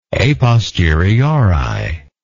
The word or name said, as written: a posteriori